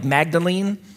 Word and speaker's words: Magdalen: It's spelled like Magdalene, but the English say Magdalen. I have no idea why.